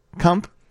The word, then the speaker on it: Kump: Is that correct? Kump? K-U-M-P? Okay. I'm just going to leave that one alone. – You got the pronunciation down. It's all good.